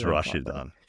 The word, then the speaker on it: Rashidun: And that is definitely not how anybody's going to pronounce that besides Rashidun. It's Rashidun.